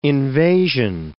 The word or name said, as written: invasion